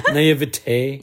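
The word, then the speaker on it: naïveté: How do you say that word? – Naivete. – Yeah, naivete.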